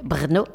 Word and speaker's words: Brno: C'est impossible à dire, mais je le répète, Brno, ça s'écrit B-R-N-O.